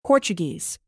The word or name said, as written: portuguese